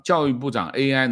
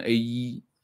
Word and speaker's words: AI: 你看看那个教育部长 AI 能够读成 A1 啊，这个司法部长我们就不说了，多了，这个团队不行。